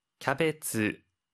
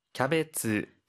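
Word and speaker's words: キャベツ: 캬베츠, 캬베츠, 캬베츠, 양배추입니다. – 피망, 피망. – 피망, 피망인데요. 혹시 발음 팁 있을까요? – 하이, 양상추와 양배추는 레타스, 캬베츠와 같이 영어를 일본어식 발음으로 표현하니 주의해 주세요.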